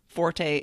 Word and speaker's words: forte: And if you're talking music, of course it's forte every time.